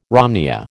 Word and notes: romneya: American English

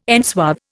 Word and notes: enswathe: Indian

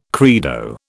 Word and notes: credo: British English

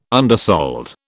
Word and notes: undersold: British English

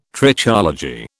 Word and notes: Trichology: British English

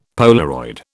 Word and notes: Polaroid: British English

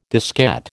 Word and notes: diskette: American English

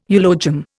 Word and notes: eulogium: Indian

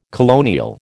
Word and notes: colonial: American English

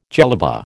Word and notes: jellaba: American English

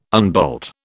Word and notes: unbolt: British English

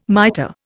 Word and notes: mitre: Indian

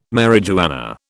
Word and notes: marijuana: British English

